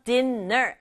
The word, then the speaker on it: dinner: 영어에서는 같은 글자 또는 같은 소리 나는 글자 두 개가 같이 오면 한 번만 발음해도 된다. 그래서 dinner 아니고 dinner 이렇게 발음하면 돼요.